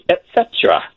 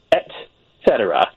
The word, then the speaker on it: et cetera: Another thing that people do, just to be on the lookout for, is sometimes they make it one syllable too short. They say et cetera, but it actually needs that other syllable in there. It's et cetera, usually spelled as one word these days in dictionaries.